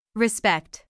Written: respect

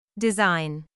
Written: design

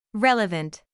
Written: relevant

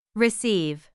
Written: receive

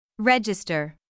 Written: register

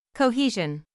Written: cohesion

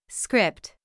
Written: script

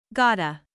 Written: gotta

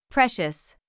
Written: precious

